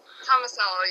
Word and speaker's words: Tomasello: Is that how you pronounce it? Tomasello? – Tomasello, yeah.